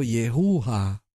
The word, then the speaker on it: Yehú: De hecho, Yehú debe de haberse pronunciado Yehuja, porque tiene una hey al final.